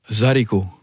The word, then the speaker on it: zydeco: C'est comme ça qu'on prononce zydeco ? – Zarico. – Zarico. – C'est comme un haricot.